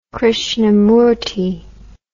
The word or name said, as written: krishnamurti